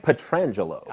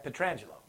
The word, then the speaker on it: Pietrangelo: How do you say his last name? – Pietrangelo. – I don't want to disrespect the dude. I can say that. Pietrangelo. – Yeah. – There it is.